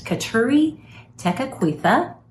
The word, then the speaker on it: Kateri Tekakwitha: The first one is that today, July 14th, is a saint day of Saint Kateri Tekakwitha.